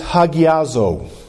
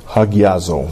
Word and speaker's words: hagiazo: In the Greek, that word is hagiazo. – En el idioma greco, significa o se pronuncia hagiazo.